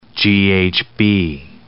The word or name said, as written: GHB